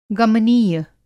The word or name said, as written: गमनीय